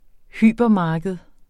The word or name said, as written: hypermarked